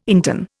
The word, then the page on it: interne: Indian